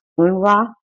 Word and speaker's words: UNRWA: We're going to talk about the organization um, pronounced UNRWA that's latest development was taking place in the Middle East.